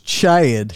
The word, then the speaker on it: Chad: It's Chad.